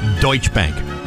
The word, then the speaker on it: Deutsche Bank: The Douche Bank upgraded Comcast Corporation to buy from hold with a price target of $32. – Steve, I believe that's pronounced Deutsche Bank.